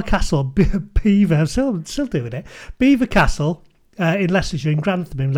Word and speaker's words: Belvoir: but I was calling it Belvoir Castle, but what's it actually called? – Beaver Castle. – Beaver Castle. Now, I have no idea. It's spelt Belvoir, but it's pronounced Beaver,